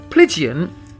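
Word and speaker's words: Plygain: But what it's called is, and I'm probably going to say this wrong, it's spelt P-L-Y-G-I-A-N, which I believe is Plygian.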